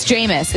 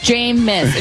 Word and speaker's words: Jameis: And we kept going, it's Jameis. It's just Jameis. And she would go like, okay. And then each time it was a different pronunciation. Everything except for Jameis.